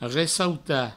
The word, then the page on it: ressautar: ressauta; Provençal